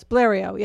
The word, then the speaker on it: Blériot: Well, what do you mean with Blériot crashes? – Yes, Blériot, yeah.